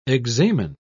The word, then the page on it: examen: British English